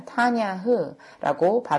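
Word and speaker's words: 타냐흐라고 (תנ״ך): 토라, 즉 율법과 가르침의 부분과 선지서들로 구성된 네빔, 그리고 시편과 문학작품들로 구성된 케투빔으로 구성되어 있었고 그각 부분의 첫 글자를 따서 TNK라고 표기되었으며 타냐흐라고 발음되었습니다.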